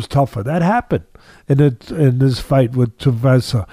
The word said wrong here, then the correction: Tuivasa: Uh, uh, Tavasa. How am I pronouncing? Tui tu, Tai Tuivasa. – Tuivasa,